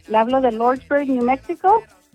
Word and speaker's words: Lordsburg: Se escribe L, Lord, y lo S, Berg, Lordsburg. – Lordsburg. – Pero para uno... Lordsburg, sí, exacto, New Mexico.